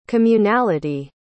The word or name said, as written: communality